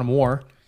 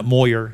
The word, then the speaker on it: Moir: M-O-I-R. How she gets that out of that, I don't know, but that's the way it's pronounced. – I thought it was Moore. – No, it's Moyer.